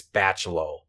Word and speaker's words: Bachalo: And it is drawn by Chris Bachalo. Mm-hmm. I'm sorry if I said that wrong. You said it right.